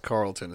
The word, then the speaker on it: Charlton: I think it's pronounced Carlton. It's just spelled weird. – Oh, well, it's stupid. – It's got the C-H Carlton.